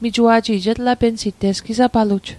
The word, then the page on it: etlapensit: Mi'gmaq